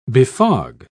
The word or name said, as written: befog